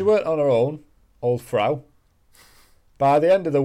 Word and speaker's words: Frau: And a woman referred to as Fra Trophy. Don't know. Fra Trophy, does that sound right? Is that a name in French? – Frau. – Frau, yeah, Frau. It's spelled like that.